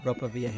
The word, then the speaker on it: Ropa Vieja: Ropa Vieja.